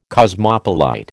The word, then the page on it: cosmopolite: American English